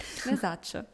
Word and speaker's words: Versace: Ikone Gianni, ich, wie gesagt, keine Ahnung, wie der Name ausgesprochen wird, Versace.